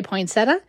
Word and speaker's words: poinsettia: A note about pronunciation, I definitely say poinsettia. I've heard, and the internet has told me, that some people say poinsettia, and some people say poinsettia, and no, poinsettia.